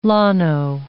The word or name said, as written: llano